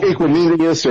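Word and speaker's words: Equanimeous: Equinemius or however you say his name. – Yeah, you said it right.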